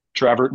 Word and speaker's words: Traverse: So how do you say Traverse City, Michigan when you say it? – Traverse, just like you did, Traverse City.